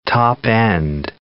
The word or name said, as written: top-end